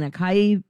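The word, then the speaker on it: açaí: A-C-A-I. I've been saying acai powder, stuff like that. It's really ah and then sai and then e, acai. That's how you say it.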